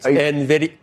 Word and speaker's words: NVIDIA: I like to pronounce the N. – That's wrong. It's NVIDIA.